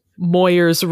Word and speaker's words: Moyers: I mean, I was thinking I would love to change my last name to just Moyers rhymes with lawyers so that people stop pronouncing it wrong. – The whole thing is a sentence? – No, it would be Moyers hyphen rhymes with lawyers, all one word.